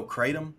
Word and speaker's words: kratom: Is it is it's kratom? – Oh, kratom. Kratom, yeah, you know, kratom uh, here in Tennessee, it's sold at a lot of gas stations, uh, different parts of the country.